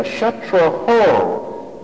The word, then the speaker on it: Kshatraho: They said Indra Kshatro instead of Kshatraho. So it changed the meaning. Instead of the enemy of Indra, it now means he who Indra is the enemy of. In other words, he who Indra can kill. One syllable mispronounced.